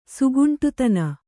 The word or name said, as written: ಸುಗುಂಟುತನ